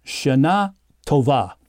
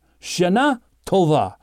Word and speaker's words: Shana Tova: And this new year is, it's pronounced something like Shana Tova, which means good year, good year, a good year, Shana Tova.